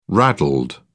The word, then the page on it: raddled: British English